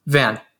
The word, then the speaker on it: Vann: Vann.